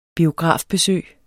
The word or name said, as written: biografbesøg